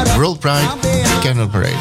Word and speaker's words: World Pride Candle Parade: Zeg ik het goed? World Pride Candle Parade. Ja, parade. Eén van de hoogtepunten van World Pride 2026.